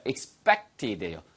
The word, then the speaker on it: expected: E-X-P-E-C-T. 됐죠? This isn't what I expected. 발음 하나 주의하세요. 쌤, 이게 P 발음인데 어떻게 expect이 아니고 expect이십니까? 그래서 영어에서는 S 발음 다음에 예, 바로 이렇게 PTK가 나오면 뿌뜨끄가 된다고 여러 차례 말씀드렸어요.